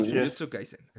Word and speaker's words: Jujutsu Kaisen: Y nuestro siguiente episodio que se tratará acerca de Jujutsu Kaisen. ¿Sí lo dije bien? – Ah, Jujutsu Kaisen.